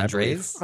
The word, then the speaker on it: Andrzej: Andrzej Sapkowski? – Sapkowski, Andrzej.